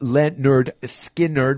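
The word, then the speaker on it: Lynyrd Skynyrd: From their album, pronounced Leonard Skinner.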